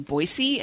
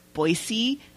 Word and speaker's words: Boise: Then we have an email from Casey in Boise, and yes, it is Boise and not Boise. There is no Z.